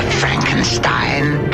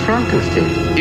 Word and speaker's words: Frankenstein: Dr. Frankenstein. – It's pronounced Frankenstein.